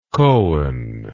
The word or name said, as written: kohen